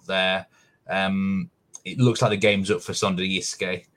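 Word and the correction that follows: Sønderjyske: Secondly, I've had some very helpful people on Twitter tell me how to pronounce these. So Sunayuska and Vela are both looking like, at the moment, relegation is going to happen, unfortunately.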